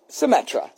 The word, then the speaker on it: Symetra: Is it Symetra? Can you tell me the pronunciation of that word? – Symetra.